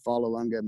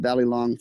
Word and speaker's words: Vollelunga: So it just makes sense, and Vollelunga Farms has a nicer ring than Vogelungela. – And it's better than Hidden Valley. – Because nobody can pronounce my name right, not even me.